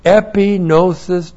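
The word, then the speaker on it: epinosis (ἐπίγνωσις): caused to be renewed in his thinking because of epinosis doctrine in his heart. E-P-I-G-N long O-S-I-S.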